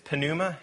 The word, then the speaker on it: pneuma: If you look at the word for spirit, either in the Old Testament, it's ruach, in the New Testament, it's pneuma, and you are supposed to pronounce the P because it's Greek, not English.